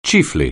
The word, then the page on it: chiefly: English